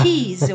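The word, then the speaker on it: Keyes: Keys, eu acho que se pronuncia assim, o Daniel Keys. Se escreve Daniel normal e K-E-Y-E-S.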